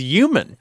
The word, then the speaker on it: human: We get emails frequently about the word human because there are certain people who don't pronounce the H. For example, Terry Gross says human without the H.